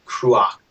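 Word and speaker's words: Cruach: Krua.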